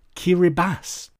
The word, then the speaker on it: Kiribati: Also, Kiribati is pronounced Kiribati.